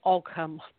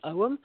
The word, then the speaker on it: Ogham: It's often, people often say Ogham, but it's actually Owen, like you owe him money. That's how you pronounce it.